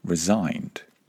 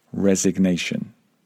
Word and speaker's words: resignation: She actually resigned. That's another important business verb. and the relevant noun would be resignation. Be careful with the pronunciation there. To resign, resignation.